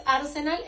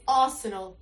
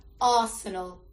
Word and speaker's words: Arsenal: Tercero, no es Arsenal, es Arsenal. La R no se pronuncia y la E y la A tienen el mismo sonido, Arsenal.